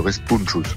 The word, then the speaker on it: Respunchus: Vous avez bien prononcé, il y a la question du S qui se prononce ou se prononce pas, mais le plus important c'est de bien mettre l'accent tonique sur le N qui est en plein milieu du mot, le Respunchus.